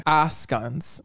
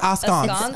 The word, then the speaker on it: askance: The first time we did word of the week, I deliver the word askance. What is it? Askance. – Askance. – Askance. – Yeah, you got to say it with like. – Yeah, askance. Like you're from France.